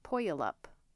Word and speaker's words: Puyallup: Like, I remember probably the first year I lived out here, there's a town called, this is how it's spelled, P-U-Y-A-L-L-U-P. So the second part's like Gallup. So I always thought it was like Puyallup. No, it's Puyallup.